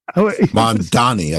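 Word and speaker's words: Mamdani: You mentioned the Mondavi Is it Mandavi? – Mandani, I think it is. – Mandani, thank you.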